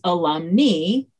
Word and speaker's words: alumni: It's because we learned that in Latin, the long I at the end of the word is pronounced like the E in knee. Now, if you follow Fran Becque's blog or Facebook page, you know that she embraces the more modern pronunciation of these words and says alumni ends in AE as alumni, and then alumni ends in I as alumni, like eyeball.